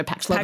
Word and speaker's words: Paxlovid: And there's Paxlovid. I don't know how you pronounce it. Paxlovid or Paxlovid.